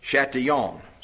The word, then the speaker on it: Chatillon: He filled his castle, Chatillon, C-H-A-T-I-L-L-O-N. At least that's where we pronounce it in West Virginia.